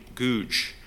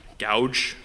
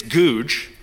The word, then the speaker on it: Gouge: You know, for example, there's an old English Puritan, a man by the name of William Googe. I think that's how you say his name. Gouge, that sounds a little violent. So I think it's Googe. He was a minister who was present at the Westminster Assembly.